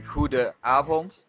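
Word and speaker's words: Goedenavond: The N is silent in this case. – Abend. – No. The first N. Guten Abend. Okay, so... You say it's silent, though? – Yeah, it has the word Hudenavond has two N's in it or only one? – Yeah, it has two. Huden, well, it would be like Hudenavond. – Yeah. the first N you don't really pronounce, as in Goede Avond.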